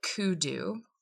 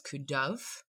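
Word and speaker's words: Cù Dubh: The band is called Kudu, sometimes referred to in Scottish as Kuduv, and it's spelled C-U space D-U-B-H.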